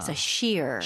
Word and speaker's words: sheer: 패션에 이제 얘기를 하는데, 일단 영어로 발음하실 때는 R 발음 끝까지 잘 해주셔야 돼요. – 아, Sheer. – sheer.